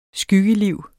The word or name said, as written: skyggeliv